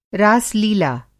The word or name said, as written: रास-लीला